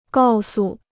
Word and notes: 告诉: gào su

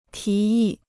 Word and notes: 提议: tí yì

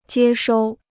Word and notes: 接收: jiē shōu

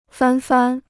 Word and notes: 翻番: fān fān

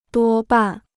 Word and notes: 多半: duō bàn

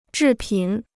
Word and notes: 制品: zhì pǐn

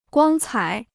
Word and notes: 光彩: guāng cǎi

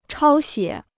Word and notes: 抄写: chāo xiě

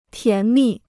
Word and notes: 甜蜜: tián mì

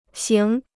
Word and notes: 型: xíng

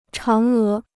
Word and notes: 嫦娥: cháng é

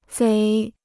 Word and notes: 贼: zéi

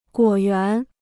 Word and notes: 果园: guǒ yuán

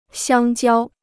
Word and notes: 香蕉: xiāng jiāo

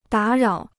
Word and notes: 打扰: dǎ rǎo

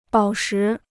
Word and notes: 宝石: bǎo shí

